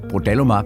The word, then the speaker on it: Brodalumab: Udtaler det rigtigt? – Det hedder Brodalumab, eller handelsnavnet Kyntium.